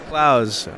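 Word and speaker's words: Clowes: Okay, so I'm here with Daniel Kloes here at APE. Dan, how's it going? – It's going well. My name is pronounced Klaus, actually. – Klaus, I'm so sorry.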